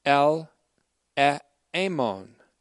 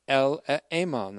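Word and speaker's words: El Eamon (ἐλεήμων): El Eamon. El Eamon. That's ELE, long E, M, long O, N. It's related to the word eleos, or mercy. Merciful and faithful.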